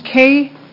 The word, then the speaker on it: Kae: My name is Kay Seth, and my first name is spelled K-A-E just because it's not usually K-A-Y for me.